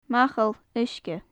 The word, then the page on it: machall-uisge: gaelic